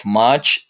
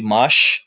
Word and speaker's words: much: And another thing is that, for instance, your pronunciation of much is not the right one because you always say mush. So the sound is not like when you shut up a person, when you say shh, when you shush a person, yeah. Instead, you should do like ch ch, like the pronunciation of church, chill, and things like that. So it's more like ch, not sh.